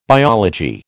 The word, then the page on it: biology: American English